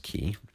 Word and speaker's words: Cay: I should also point out the Key is spelled C A Y for the first time is there, but it's pronounced Key.